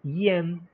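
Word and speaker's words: Ihen: La octava se llama IEN, pero acá dice que se escribe con una H, o sea que se escribe I, la H, la letra E, o sea, I latina, H, E, N, nada más que no se pronuncia la H, o sea, se pronuncia, se llamaría IEN, que quiere decir unidad.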